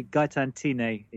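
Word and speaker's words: Gaëtane Thiney: Gaetan Tine is the correct pronunciation, but you got the surname right.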